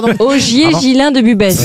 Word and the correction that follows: Busbecq: Augier Gillin de Busbecq, pardon, j'ai écorché son nom. – Il semble bien aussi. – Ça, c'est pour ça, oui. – Est-ce que vous pouvez appeler Busbecq? – B-U-S-B-E-C-Q. Olivier, pardon, Augier Gislin de Busbecq.